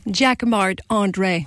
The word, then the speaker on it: Jacquemart-André: Jacques Marc André.